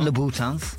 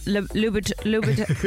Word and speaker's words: Louboutin: Louboutin. No, that, you're not saying it right. It's not Louboutin. It's Louboutin. Louboutin.